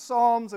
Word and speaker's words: Psalms: Like, I mean, phonics is about pronouncing things according to how they're spelled, and phonics is spelled with a P. So is Psalms, all right? We pronounce it Psalms, and we're just messing around by calling our current series the Psalms of Summer, both with a P.